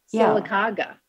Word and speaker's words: Sylacauga: Sylacauga.